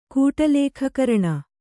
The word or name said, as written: ಕೂಟಲೇಖಕರಣ